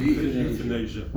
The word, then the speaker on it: euthanasia: Euthanasia. – Euthanasia. – Euthanasia. Euthanasia. – It's nothing to do with youth or age.